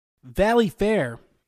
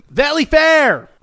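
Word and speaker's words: Valleyfair: Valley Fair has announced... – Hey, you didn't say it right. – Valley Fair! – There you go.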